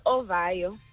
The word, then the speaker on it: Ovayo: Ovayo, is that the correct pronunciation? – Yes, Ovayo. – Ovayo. Ovayo. Soft yes. O at the end.